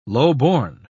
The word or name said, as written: lowborn